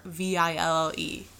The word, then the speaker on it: Courville: So, Dr. Cruelville proposed that they use... Cruella de Vil. – Also, Cruelville, if that's his name, is, like, just... – It's me not knowing how to pronounce French. It's Courville.